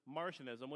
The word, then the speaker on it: Marcionism: First one is Martianism, which is the one that both Merriam-Webster and Britannica state is the right pronunciation for it.